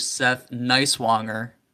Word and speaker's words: Seth Nighswonger: So tonight we're joined by Mr. Seth Nighswonger.